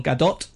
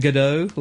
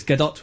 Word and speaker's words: Gadot: Wonder Woman's character, as played by Israeli actress Gal Gadot, and that is pronounced like that, Gadot, with a hard T, and everybody is saying Gadot, like she's French or something, but that's not how you say it.